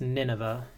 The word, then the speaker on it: Nineveh: I think it's pronounced Nineveh.